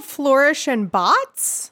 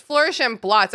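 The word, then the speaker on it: Flourish and Blotts: Is it really called Flourish and Bots? – No, it's Flourish and Blots. I probably said it wrong.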